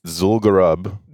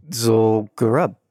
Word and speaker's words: Zul'Gurub: Zul'Gurub. – Zul'Gurub.